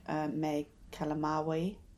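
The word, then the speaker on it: May Calamawy: And I'm probably going to mention a number of times, there's a really good Empire podcast interview with Ethan Hawke, Oscar Isaac, Mae Kalamaui, as it sounds like that's how it's pronounced.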